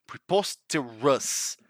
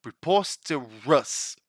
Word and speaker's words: preposterous: Mind the pronunciation of that final syllable, preposterous, preposterous. Two examples for you.